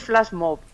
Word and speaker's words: flash mob: nos recordaras dónde podemos encontrarnos, dónde podemos, el que quiera y esté interesado, ponernos en contacto para llevar este flashbook, no sé si lo he pronunciado mal, corrígeme, No, no, Flash, bueno, sí, es Flash Mob. – Es Flash Mob. – Sí, Mob.